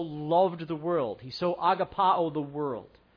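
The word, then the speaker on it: agapao (ἀγαπάω): is the word agapao, or you may have heard it pronounced agape. Agapao.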